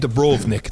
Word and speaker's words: Dubrovnik: In Dubrovnik.